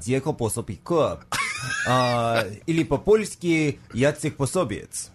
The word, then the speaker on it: Posobiec: So I think, as well as I'm here with my Hungarian friend, we can go with the official Polish pronunciation. In English, we usually say Posobiec, but yeah, for Polish, it would be Pособiec.